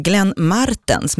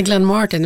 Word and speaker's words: Martens: Glenn Martens med betoning på R-et. Vem är det? – Ja, det är inte Glenn Martin, utan Glenn Martens.